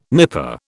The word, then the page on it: nipper: British English